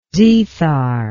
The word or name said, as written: zethar